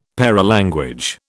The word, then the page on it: paralanguage: British English